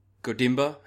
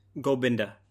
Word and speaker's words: Gobinda: Godimba? How do you say it? – Gobinda.